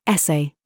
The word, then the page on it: DRESS: British English